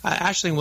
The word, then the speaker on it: Aisling: Aisling, welcome to the show.